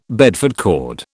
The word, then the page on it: Bedford cord: British English